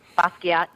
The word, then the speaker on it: Basquiat: Is it Basquiat? Is that how you say his name? – Basquiat, yeah. – Basquiat. I'm the Basquiat of teasing. – That's how I pronounce it, Basquiat.